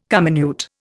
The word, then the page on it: comminute: Indian